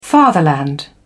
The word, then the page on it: fatherland: British English